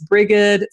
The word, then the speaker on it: Brigid: But in her pagan incarnation, I guess that's the right word, as a pagan goddess, she's known as Brigitte, B-R-I-G-I-D. And there's not a lot of unanimity on how to say that. Sometimes it's Brie, sometimes it's Brigid, sometimes it's Brie. I'm just saying Brigitte for sake of ease. And then in Christianity, it's also B-R-I-G-I-D. Same pronunciations, but it becomes Brigitte in the kind of anglicized version that we have now.